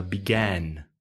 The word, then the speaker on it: began: But it's quite difficult for Spanish speakers to pronounce the ah and differentiate, to notice the difference between ah and ah. Began, begun.